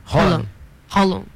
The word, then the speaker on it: Haaland: Rolam.